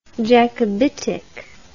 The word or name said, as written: Jacobitic